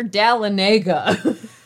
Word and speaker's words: Dahlonega: It's near Dahlonega. Wait, as Jason would say. – That's the way I pronounce it when I arrived here. Oh, Dallinega.